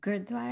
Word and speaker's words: Gurdwaras: I always say it wrong, Godorda, did I say it right? – Gurdwaras. The Gurdwaras. – Yeah. – There you go.